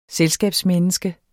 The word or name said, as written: selskabsmenneske